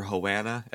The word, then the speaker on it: Havana: Hell yeah, that was Sister Havana, or Hoana, as Nash Cato pronounces it.